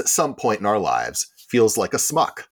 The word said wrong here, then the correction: schmuck: A schmuck. It's a schmuck. – Smuck. – It's pronounced schmuck.